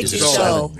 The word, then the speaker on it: Tussaud: I say Tussaud. – Yeah. – I've heard Tussaud, no D.